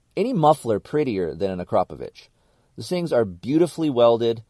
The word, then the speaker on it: Akrapovic: I felt like shit, but he made me say Akrapovic about 50 times before we went to go eat lunch. So thank you for teaching me the right way, but that is how you say it.